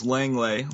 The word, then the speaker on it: Langlais: I did not know it was Langlais. Langlais, that's what I always said, but I apologize. Ahmir Langlais. The S is silent,